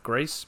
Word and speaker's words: Gris: I found out it's pronounced Greece, but most people might call it Gris, G-R-I-S.